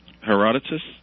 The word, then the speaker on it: Herodotus: Herodotus? I don't know where you're going, but he was the father of history. – Yeah, I have to check my pronunciation because you got it right.